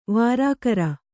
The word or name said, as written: ವರಕರ